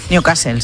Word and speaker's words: Newcastle: Newcastle, sí.